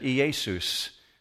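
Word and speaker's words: Iesous: In Greek, in the Greek world, if you were living at that time, you wouldn't have called Jesus, Jesus. You would have said Iesus. But Iesus is the Greek pronunciation of the Hebrew name Yeshua.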